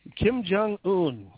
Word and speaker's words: Kim Jong-un: So Kim Jong-un, or Un, I guess is how you would pronounce it, the leader of North Korea.